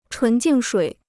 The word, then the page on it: 纯净水: chún jìng shuǐ